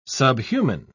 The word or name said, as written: subhuman